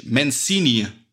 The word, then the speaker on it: Mancini: Äh, Mancini. Mancini. Sicher? Ja, hätte ich jetzt gesagt.